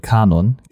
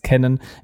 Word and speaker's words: Canon: Es gab das krasseste Kamera-Event aus den letzten zehn Jahren von Canon oder wie manche sagen würden Canon. Gibt auch Leute, die es so aussprechen. Ich gehöre nicht dazu. Für mich ist es Canon. Ich spreche es Englisch aus und das war ein Event,